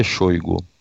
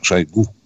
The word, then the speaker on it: Шойгу: назначили Шойгу? Кстати, Сергей Кужгыджич упорно говорит, чтобы ударение делали мы на Шойгу.